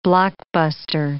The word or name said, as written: blockbuster